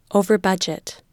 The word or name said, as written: overbudget